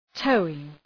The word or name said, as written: towing